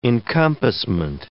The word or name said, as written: encompassment